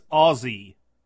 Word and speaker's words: Aussie: I think it's Aussie. It's more, it comes off like a Z.